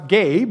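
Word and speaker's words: ge (γῆ): The word that Jesus uses here in verse 13 was the Greek word pronounced ge, but it's spelled G-E.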